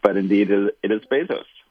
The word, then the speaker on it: Bezos: Bezos or Bezos? How do you pronounce his name? – It is Bezos, yes.